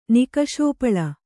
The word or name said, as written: ನಿಕಶೋಪಳ